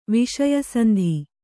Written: ವಿಷಯಸಂಧಿ

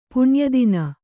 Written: ಪುಣ್ಯದಿನ

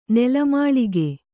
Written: ನೆಲಮಾಳಿಗೆ

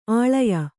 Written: ಆಳಯ

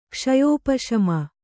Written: ಕ್ಷಯೋಪಶಮ

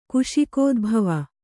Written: ಕುಶಿಕೋದ್ಭವ

